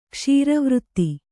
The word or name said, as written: ಕ್ಷೀರವಾರಿಧಿ